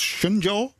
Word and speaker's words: Shenzhou: Prachtig, en ik heb het eigenlijk spreek je het uit als Shenzhou. Dat heb ik een keertje in Amsterdam aan een Chinese winkelier gevraagd.